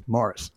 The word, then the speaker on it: Maurice: It's spelled Maurice, but it's pronounced Morris.